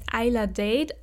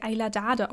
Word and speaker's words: Ayla Dade: Äh, es war von, ich weiß nicht genau, wie sie, ich habe in meinem Kopf immer gedacht, sie heißt Ayla Date, aber ähm, ich habe in einem anderen Podcast gehört, da wurde sie Ayla Dade ausgesprochen. ähm, auf jeden Fall sieht man die gerade auch ganz viel, also ihre Bücher äh, auf Bookstagram und TikTok und so.